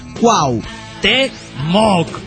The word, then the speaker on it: Cuauhtémoc: Che, pirata, desde hace algunos tiempos te quería decir que pronuncias mal la palabra Cuauhtémoc. Dice, hermano, repite después de mí. Cuauhtémoc.